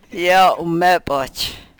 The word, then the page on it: yo u-mepoch: Yurok